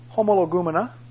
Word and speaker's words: homologumena: The first is the homologumena. You can spell that. Spelling's up here. H-O-M-O-L-O-G-U-M-E-N-A.